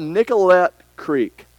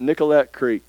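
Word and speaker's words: Nicollet Creek: It's called Nicolet Creek. That's how I pronounce it. Nicolet Creek. N-I-C-O-L-L-E-T. Nicolet Creek.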